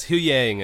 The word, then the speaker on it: Huyang: Is, how do you say, is the droid's name Huang? – It's Hu Yang.